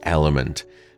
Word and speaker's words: LMNT: A sponsor of today's podcast is Element, and that's spelled L-M-N-T.